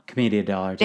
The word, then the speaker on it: Commedia dell'arte: Commedia dell'arte.